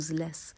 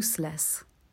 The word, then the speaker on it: useless: به تلفظ صفت useless هم دقت کنین. نمیگیم useless، میگیم useless.